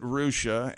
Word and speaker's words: Russia: Fort Recovery is by R-U-S-S-I-A. – Is that where the rehab is? – You pronounce it Rusha, and there's a Houston there, which is spelled H-O-U-S-T-O-N.